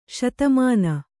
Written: ಸೌದಾಮನಿ

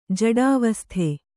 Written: ಜಡಾವಸ್ಥೆ

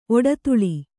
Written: ಒಡ್ತಲಿ

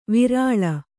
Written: ವಿರಲ

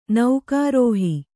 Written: ನೌಕಾರೋಹಿ

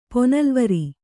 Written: ಪೊನಲ್ವರಿ

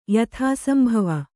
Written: ಯಥಾಸಂಭವ

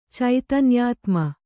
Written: ಚೈತನ್ಯಾತ್ಮ

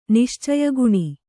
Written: ನಿಶ್ಚಯಗುಣ

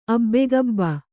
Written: ಅಬ್ಬೆಗಬ್ಬ

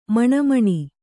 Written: ಮಿಣಮಿಣ

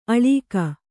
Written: ಅಳೀಕ

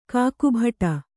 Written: ಕಾಕುಭಟ

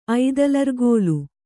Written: ಐದಲರ್ಗೋಲು

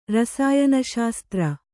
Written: ರಸಾಯನಶಾಸ್ತ್ರ

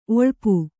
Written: ಒಳ್ಪು

